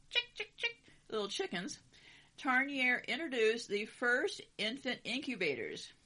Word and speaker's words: Tarnier: In the 1870s, Etienne Stepanet, you know I don't know how to pronounce these right, Tarnier, key word here, T-A-R-N-I-E-R, Tarnier, a physician, began to study the problem of premature infants and found that maintaining a constant temperature was not enough.